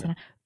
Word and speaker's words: Cena: Eric Burns Marsh, shame on John Cena. That's how you say his name, right? Senna? – Yeah. – John Cena.